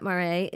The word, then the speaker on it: Maré: Jeannette Maré is the founder and executive director of Ben's Bells Project.